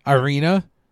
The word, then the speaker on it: Irina: I never know how to pronounce her name. Is it Erna or Irina? – I thought it was Irina. – Irina? – Irina, Irina.